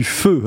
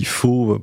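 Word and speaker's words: phở: Alors, vous êtes spécialiste, je crois, après vos années passées là-bas, du feu. Alors, nous, on dit faux pour ceux qui ne savent pas, donc il faut prononcer feu.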